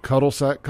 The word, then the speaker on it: cul-de-sac: Oh, but it's cul-de-sac, cul-de-sac. You still say it the same.